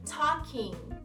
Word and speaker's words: talking: Falando, talking e também na palavra falando, o L é mudo, praticamente. Então, talking, talking.